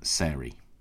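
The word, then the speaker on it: Sairie: Very happy to give Sairy, S-A-I-R-I-E, a mention, particularly because, I say, I often listen to Sombrero Fallout and always look forward to the next theme. So thank you, Emma, from Sairy, pronounced like fairy, so correctly pronounced by me.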